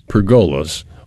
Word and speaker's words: pergola: But we're going to talk first about pergolas, or some people say pergolas, or whatever they... A pergola is how you say it.